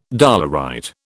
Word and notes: dolerite: British English

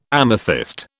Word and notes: amethyst: British English

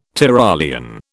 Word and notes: Tyrolean: British English

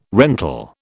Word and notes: rental: British English

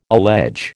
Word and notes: allege: American English

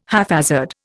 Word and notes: haphazard: Indian

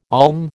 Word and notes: olm: American English

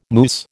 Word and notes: moose: American English

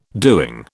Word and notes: doing: British English

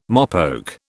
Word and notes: mopoke: British English

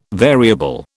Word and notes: variable: British English

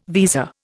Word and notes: visa: Indian

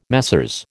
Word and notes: Messieurs: American English

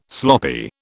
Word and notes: sloppy: British English

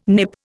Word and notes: Nip: Indian

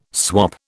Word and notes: swap: British English

